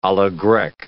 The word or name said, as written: a la grecque